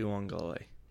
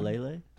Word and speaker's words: Uiagalelei: In order to beat Clemson, they need to get after DJ Uiagalelei. I don't even know if I pronounced that right, but you're fine. You're fine. – Uiagalelei? – No, you're good.